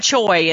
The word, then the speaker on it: Choi: Dan Choi, and it's spelled Dan, of course, D-A-N, and Choi is C-H-O-I. – Oh, like boy with an I.